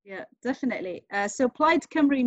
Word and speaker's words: Plaid Cymru: And the party that you're running for is called Plaid Cymru, is that right? – Correct. I'm really impressed you pronounced it right.